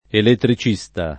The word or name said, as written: elettricista